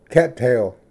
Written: ᏪᏌ ᎦᏙᎦ

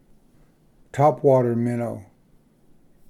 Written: topwater minnow

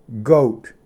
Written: ᎤᎦᏐᏣᏁᏓ

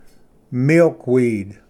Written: ᎠᏔᏍᎩᏍᎩ

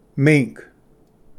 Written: ᏒᎩ

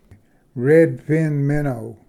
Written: redfin minnow

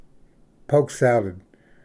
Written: poke salad

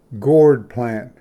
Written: ᎦᎸᏂ

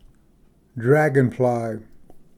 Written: dragonfly green large

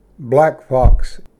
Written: ᎢᏃᎵ

